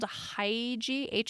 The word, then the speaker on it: hygge: It's spelled hygge, but it's pronounced hygge.